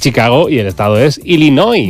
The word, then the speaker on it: Illinois: Se pronuncia Illinois. – Sí, perdón, sí. – Illinois. – Pues yo digo Illinois, y no entendemos todo. Porque como diga Illinois, nadie lo entiende.